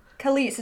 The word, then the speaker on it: Kelis: Kelis, is that how you say it? – Uh-huh.